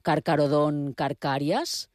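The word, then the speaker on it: Carcharodon carcharias: Bé, si els noms de les espècies són en llatí, sempre donat per fet que he de pronunciar la CH com cap, perquè jo sempre dic, per exemple, Carcarodon carcàries, i a vegades s'han sorprès perquè ho dic així. Això seria el correcte o no? – No, la pronunciació correcta és la pronunciació llatina.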